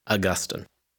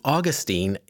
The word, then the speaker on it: Augustine: I had a church history professor in seminary who liked to say, St. Augustine is in Florida, St. Augustine is in heaven. So let's go with that. I'm struck by the very first word in Augustine's classic book, The Confessions.